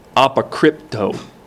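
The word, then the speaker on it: apokrypto (ἀποκρύπτω): That word hidden is the Greek word apokrypto. Apokrypto. It kind of sounds like the word cryptic, doesn't it?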